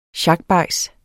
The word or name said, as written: sjakbajs